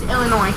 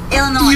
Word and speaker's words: Illinois: Don't pronounce the S in Illinois. – It's just Illinois. – Illinois.